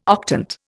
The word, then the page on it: octant: Indian